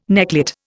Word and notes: necklet: Indian

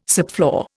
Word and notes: subfloor: Indian

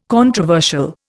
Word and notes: controversial: Indian

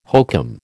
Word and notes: hokum: American English

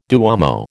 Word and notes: duomo: American English

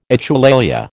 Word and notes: echolalia: American English

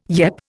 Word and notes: yep: Indian

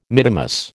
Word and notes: mittimus: American English

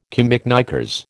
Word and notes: camiknickers: American English

